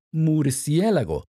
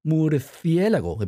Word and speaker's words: murciélago: In Spanish, it's murciélago, or murciélago, if you want to say it as they pronounce it in Spain,